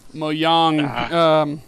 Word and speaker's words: Mojang: The J is a nice Y. It's a soft J. – Mojang.